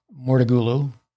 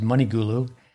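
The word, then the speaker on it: Mouratoglou: You could pass on this as well, but I know that Serena is no longer working with Patrick Mortegulu. I've mispronounced the name before as Moneygulu.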